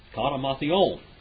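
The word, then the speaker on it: Katamathion (κατὰ Ματθαῖον): Katamathion. That's how you say it in Greek.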